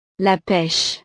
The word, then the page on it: la peche: frances